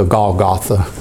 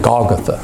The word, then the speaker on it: Golgotha: Lee said, don't ever pronounce Golgotha, Golgotha. Apparently, he knew some reason why that should. He said, always pronounce it Golgotha.